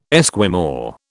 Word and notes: Esquimau: British English

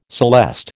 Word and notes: celeste: American English

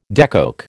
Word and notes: decoke: American English

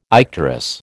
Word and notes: icterus: American English

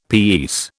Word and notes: piece: British English

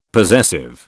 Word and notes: possessive: British English

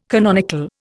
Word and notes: canonical: Indian

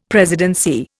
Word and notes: presidency: Indian

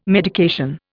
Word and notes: medication: Indian

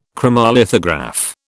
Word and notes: chromolithograph: British English

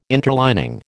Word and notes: interlining: American English

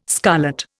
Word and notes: scarlet: Indian

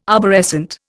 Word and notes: arborescent: Indian

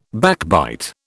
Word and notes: backbite: British English

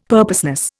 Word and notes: purposeless: Indian

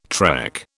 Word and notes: trek: British English

